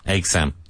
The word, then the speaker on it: exam: Yeah. Like eggs and Sam. – Hmm. – Exam.